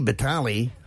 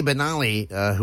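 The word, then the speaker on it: Banali: Sadly, yesterday we lost Frankie Benali. Benali, I said that wrong. Frankie Benali, who was drummer with Quiet Riot from the original lineup until he passed away yesterday.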